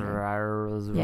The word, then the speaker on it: Saoirse: Saoirse Ronan. – That's exactly how you pronounce it. – Saoirse Ronan and Romola Garai.